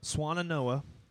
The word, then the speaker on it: Swannanoa: Swannanoa.